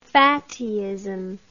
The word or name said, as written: fattyism